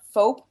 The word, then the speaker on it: FOAP: Have you heard about FOAP? – No, what's that one about? – It's spelled like soap, but with an F.